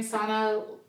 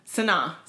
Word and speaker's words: Sanaa: People saying Ashanti looked like, what's her name, Sana? – Who? – How do you say her names? – I don't know who you're talking about. – The girl from Love and Basketball. – Sanaa.